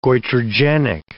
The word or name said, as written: goitrogenic